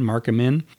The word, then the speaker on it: Amin: Last year, we had a case brought by a guy named Mark Amin, I guess it's pronounced, A-M-I-N, Mark Amin.